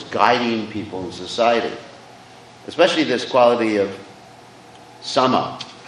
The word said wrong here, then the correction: shama: or shama, actually, I'm not pronouncing it right, shama, which means being equal poised.